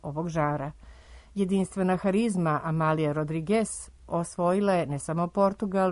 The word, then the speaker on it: Rodrigues: ostala je Amália Rodrigues ili Rodrigues, kako se to u Portugalu izgovara.